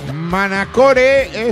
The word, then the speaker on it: Manacore: Manacore es un bonito juego de palabras que si lo pronuncias en inglés se dice Manacor, como la ciudad de las Islas Baleares.